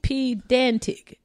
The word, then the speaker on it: pedantic: Pedantic. Okay. – Is it pen? – No, there's no N.